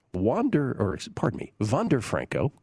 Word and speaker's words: Wander Franco: Wander Franco, correct pronunciation, 11-1.